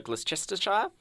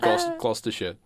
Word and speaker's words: Gloucestershire: Events of this episode is based on a real incident that took place in Stroud, Gloucestershire. – Gloucestershire. You're absolutely butchering the pronunciation of names here on this podcast.